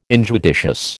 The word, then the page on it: injudicious: American English